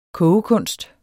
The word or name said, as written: kogekunst